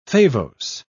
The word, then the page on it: favose: British English